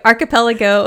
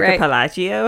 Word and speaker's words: archipelago: Archipelago. – I love how I knew that you were trying to say archipelago. – I practiced too, and I said it right. – Archipelagio? – I just could not say it.